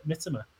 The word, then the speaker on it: Mitoma: So the correct pronunciation is Mitoma.